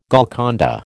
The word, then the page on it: Golconda: American English